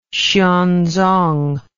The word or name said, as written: xuanzong